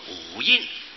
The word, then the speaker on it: 五阴: 啊，旧译写的五阴，阴阳的阴，但是那个阴阳的阴不能念作阴字，要念气声，印遮印的印，受印的印，跟那个草字头阴字的印同音，要念作五印，五蕴，五印，新译是五蕴，旧译是五印，印是遮印，被它盖辅住了。